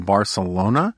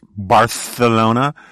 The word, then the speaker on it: Barcelona: We spent almost a week in Barcelona, which is not pronounced Barcelona in Barcelona. It is only pronounced Barcelona by the uh, Castilian hegemons who cruelly rule over what should be an independent Catalan.